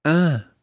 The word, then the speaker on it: un: Now this un, it's spelled U-N, and it means a, as in one. Un frère. – Un frère. – And it's got a slight nasal sound to it.